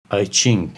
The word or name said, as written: I-Ching